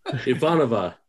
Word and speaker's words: Ivanova: Ivanova.